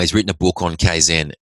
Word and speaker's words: Kaizen: So there was a Japanese principle, which is Kaizen. It's spelled K-A-I-Z-E-N. Kaizen, the definition of it is the practice of continuous improvement.